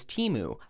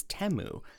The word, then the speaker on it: Temu: So my next one is what I thought was Temu. However, we learned very quickly from the earworm jingle that it is Temu. So they instantly told me that I was saying it wrong, so I didn't love that.